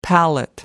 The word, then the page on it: palette: American English